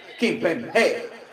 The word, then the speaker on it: Kimpembe: Le pongo la tilde, el acento en la E. Kimpembe.